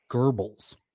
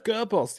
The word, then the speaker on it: Goebbels: And I know his name looks like Joseph, but I've heard it being pronounced as Joseph and the last name Goebbels. – Yep. – Joseph Goebbels.